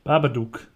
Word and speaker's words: Babadook: Und zwar ist es der, der Badabuk. – Babadook. Ba Baba, ach, Baba, Babadook, Babadook, genau. Da, das, da, das muss man schon richtig aussprechen, Babadook.